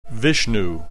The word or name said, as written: Vishnu